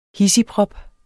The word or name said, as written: hidsigprop